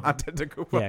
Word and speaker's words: Antetokounmpo: We all learned how to say Giannis Antetokounmpo. – Did we? – Did I just ruin my own point? – You probably did. – Antetokounmpo.